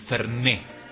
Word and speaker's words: Fernet: Ay, pero queda tan bien el Ferné, con acento en la última E, porque es aguda y termina en vocal, yo acento. El Ferné, claro, vamos a tomar un Fernet.